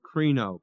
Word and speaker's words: krino: And that word is a very familiar word, krino, K-R-I-N, long O, which you've had before, which means to pronounce judgment.